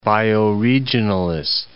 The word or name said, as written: bioregionalist